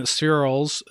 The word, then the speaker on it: spherules: If you were to take this object in the spherules, how do you say it? Spheroidal? – Spherules. So let me explain.